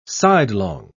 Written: sidelong

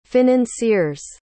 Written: financier's

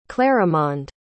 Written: clarimonde

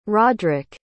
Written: roddric

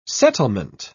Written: settlement

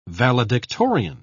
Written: valedictorian

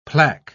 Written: plaque

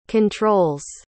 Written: controls

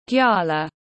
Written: Gyala

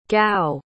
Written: gau

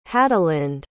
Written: hadelinde

